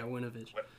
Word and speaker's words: Winovich: Yeah, Winovich.